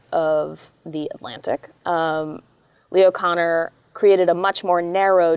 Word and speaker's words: Kanner: and the other is Leo Kanner, um, or I should say Connor, um, is how he pronounces it.